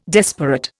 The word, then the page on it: desperate: Indian